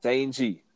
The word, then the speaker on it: Shang-Chi: After that, it's going to be Shang-Chi, if I say that right. – Shang-Chi.